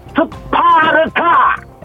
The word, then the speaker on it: 스파르타: 자, 다시 한번 정확하게 뭐라고요? – 스파르타. – 예, 스파르타요. 네. 스파르스 아니고 아.